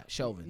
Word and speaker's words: Chauvin: Yeah, Chauvin. – Yeah, him. Like, it's about him. – Make sure we pronounce his name right.